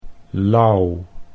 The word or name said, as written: Lauw